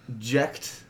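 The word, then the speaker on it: Jecht: I'm pretty sure it's pronounced Jekt. You talking about the name of the dad from Final Fantasy X? – Maybe.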